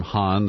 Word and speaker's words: Khan: Khan, or they call him Han, the K is silent, in Pakistan.